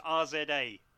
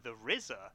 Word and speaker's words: RZA: Can I just say, has a fucking amazing moment in it where Joe thinks that the RZA from Wu-Tang Clan is pronounced RZA. She calls him RZA, and then one of the other people's like, I think you mean the RZA.